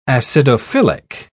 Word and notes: acidophilic: British English

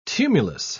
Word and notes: tumulus: British English

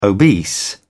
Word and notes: obese: British English

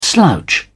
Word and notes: slouch: British English

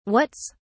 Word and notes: whats: British English

